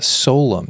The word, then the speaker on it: Solem: And in this episode, we have Matt and Julia Solem.